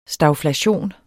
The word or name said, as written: stagflation